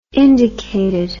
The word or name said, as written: indicated